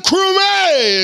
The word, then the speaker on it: Crumméy: Shaun Crumméy is here.